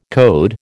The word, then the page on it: code: American English